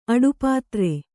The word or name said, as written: ಅಡುಪಾತ್ರೆ